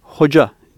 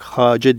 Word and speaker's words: hoca: Orta Asya ve bizim Osmanlı'da hoca diye, yani Arapça olarak Hace diye yazılsa bile biz hoca diye okuyoruz.